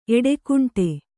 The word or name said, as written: ಎಡೆಕುಂಟೆ